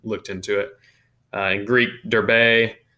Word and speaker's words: Derbe: So after they are once they leave Lystra after this, they're going to go to Derbe. That's how you say it in Greek. It's not Derb.